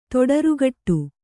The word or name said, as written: ತೊಡರುಗಟ್ಟು